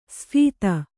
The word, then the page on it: ಸ್ಫೀತ: sphīta